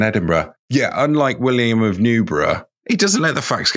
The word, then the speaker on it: Newborough: I think that's probably Newborough. Not Newburgh. – Yes. – Just if you want to record a drop-in, because he's also Newbury, so it's probably not Newburgh with a hard G.